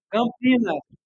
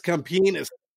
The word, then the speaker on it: Campinas: Campania? Is that another city? Campania or no? Am I saying it wrong? – Campinas. – Yes, Campinas. Oh my God, that was, I gotta tell that story.